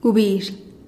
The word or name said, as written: gubizh